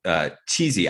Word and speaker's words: Qissi: But the role, who is supposed to be a Thai character, is played by actor Michael Chizzi. I don't know. How do you say his last name? Q-I-S-S-I? – I think you got it right.